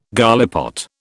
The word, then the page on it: gallipot: British English